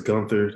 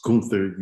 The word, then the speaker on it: Gunther: Everybody says Gunther. That's not how you say it. It's Gunther, you Americans.